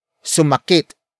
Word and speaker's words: sumakit: Word for word, it's sumakit ang tiyan niya sa kakakain.